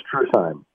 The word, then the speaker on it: Truesheim: It's a Heim name, like Sternheim, so it's Truesheim.